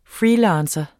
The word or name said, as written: FREELANCERE